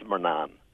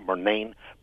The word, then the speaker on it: Murnan: And we would always refer to that name, whether it's spelled M-U-R or M-A-R, as Murnan. But in other parts of the country, they'd call it Murnane, but Murnane is generally thought to be an American pronunciation. So stick with Murnan, and you'll be fine.